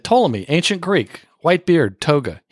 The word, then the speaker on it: Ptolemy: You know, Ptolemy is spelled with a silent P,